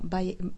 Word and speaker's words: Baiae: che è ambientato a Baiae, come preferisci? Come si dice? Baia? – No, Baiae, Baiae, quello accanto a Napoli.